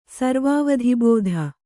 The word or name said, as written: ಸರ್ವಾವಧಿಬೋಧ